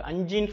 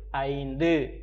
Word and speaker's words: ஐந்து: அஞ்சுன்னு சொல்லுவாங்க நிறைய பேர் அஞ்சு கிடையாது ஐந்து தெளிவாக உச்சரிக்கணும் ஐ இன் து